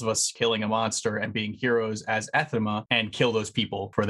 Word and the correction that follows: anathema: Anathema. – What did I say? Athema? – Yeah, you didn't even, you missed thema. Half the word that time.